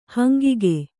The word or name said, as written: ಹಂಗಿಗ